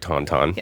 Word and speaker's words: Tauntaun: And I think I'm saying that right, Tauntaun. – Yes. – Tauntaun.